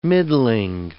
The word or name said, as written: middling